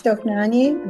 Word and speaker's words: Dohnányi: Dohnányi, did I say that right? – Yes, you did. This is Dohnányi, another Hungarian composer, and this is the Canzonetta, Opus 41.